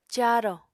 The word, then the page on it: caarò: apatani